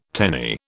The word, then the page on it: tenny: British English